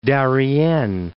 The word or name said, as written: Darien